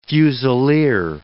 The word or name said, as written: fusilier